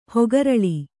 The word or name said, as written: ಹಕ್ಕೆರಲು